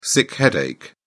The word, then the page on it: sick headache: British English